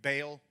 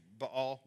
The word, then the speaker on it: Baal: And he went and served, we'll say Baal, but pronunciation is usually Baal. We'll say Baal throughout this series.